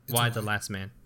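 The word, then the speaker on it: Y the Last Man: Is it E? – No, it's Y. – Is it pronounced E or is it Y? Why the Last Man.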